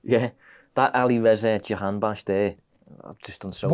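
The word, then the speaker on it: Alireza Jahanbakhsh: No, I know that's how you say it because you played for Brighton then. – Ali Reza Jahan bash.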